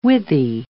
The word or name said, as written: withy